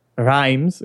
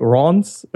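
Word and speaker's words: Reims: I think we were saying Rhymes or something like that, and the proper pronunciation is, don't quote me on this because it's from memory, but something like Rons or something pretty different.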